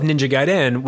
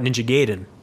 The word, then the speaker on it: Ninja Gaiden: And that's the Pernect correct pronunciation of Ninja Gaiden, which was a rarity at the time. People used to call it Ninja Gaiden.